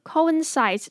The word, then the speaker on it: coincides: Often coincides， coincides 这边 coincides，它念起来就跟 inside 那个里面那个 inside 一样的发音哈。